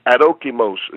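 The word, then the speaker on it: Adokimos (ἀδόκιμος): Adokimos is how you pronounce it.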